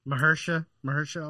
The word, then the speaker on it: Mahershala: The dude who was in the Mahershala Ali.